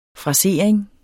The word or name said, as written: frasering